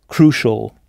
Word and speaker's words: crucial: Pas de problème avec « crucial », c'est exactement le même mot en anglais, mais prononcé « crucial ».